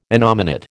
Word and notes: innominate: American English

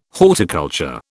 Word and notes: horticulture: British English

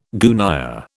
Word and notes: gunyah: British English

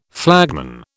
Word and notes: flagman: British English

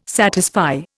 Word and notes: satisfy: Indian

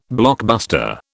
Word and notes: blockbuster: British English

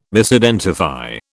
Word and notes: Misidentify: British English

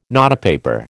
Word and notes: notepaper: American English

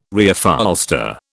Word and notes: Reupholster: British English

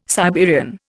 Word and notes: Siberian: Indian

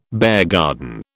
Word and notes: beargarden: British English

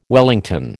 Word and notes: wellington: American English

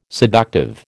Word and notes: seductive: American English

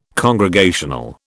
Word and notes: congregational: British English

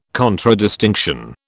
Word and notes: contradistinction: British English

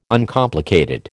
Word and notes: Uncomplicated: American English

